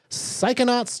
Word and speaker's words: Psychonauts: C-H-O-N-A-U-T-S. – See, I say psycho. – Psychonauts. – Psychonauts. – Psychonauts. – No. – Psychonaut, like astronauts, yes, psychonauts. – Psycho. – Like Barbie, psycho. Like astronaut Barbie, psychonaut Barbie.